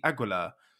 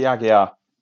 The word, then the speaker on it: Aguiar: His surname frustrates the hell out of me because it feels like there should be an L in there, and that would make it very easy. Aguilar. How are we pronouncing Ricky's surname? – Ricky Aguiar.